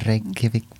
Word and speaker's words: Reykjavik: The Reykjavik Brewing Company. – Excellent pronunciation.